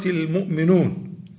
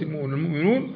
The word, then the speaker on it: المؤمنون: فتقول من سورة المؤمنون بقلب جامد، ما تخافش، من سورة المؤمنون، تضعها بين مع كفتين، أنت على الحكاية، هي في محل جر لكنها تنطق على الحكاية، على حكاية لفظ القرآن الكريم، لكن من جهة اللغة يجوز كما ضربت لك المثل.